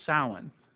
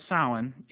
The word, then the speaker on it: Samhain: Halloween is rooted in the Celtic festival of Samhain. Well, Samhain spelled S-A-M-H-A-I-N. It looks like Sam Hain, but it's pronounced Samhain.